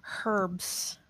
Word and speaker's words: herbs: I, I, like how I like how people in other countries say herbs. – Herbs, yeah. Well, there's a H in the beginning. You Yankees. – I, I know it's herbs. It's silent.